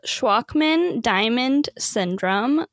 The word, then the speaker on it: Shwachman-Diamond syndrome: So it's called Shwachman-Diamond syndrome, like a Walkman, but just with an S, and then diamond, just like a diamond gem. And then syndrome. And it is a very rare disease that only affects, I think, around like 5,000 people in the whole world.